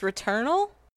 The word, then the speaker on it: Returnal: is it pronounced Returnal? – Yeah.